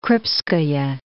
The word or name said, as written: Krupskaya